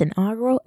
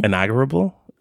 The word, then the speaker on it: inaugural: Have you been saying it? – Inaugurable? – There's no B.